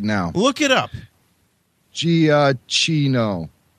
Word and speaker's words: Giacchino: It's uh, a G-I-A-C-C-H-I-N-O. M-O-U-S-E. – That's I mean, what you have to say. – yeah, I mean, the Italian would not pronounce it with a hard G. The Italians would pronounce it with a J, a soft G, Giacchino.